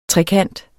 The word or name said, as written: trekant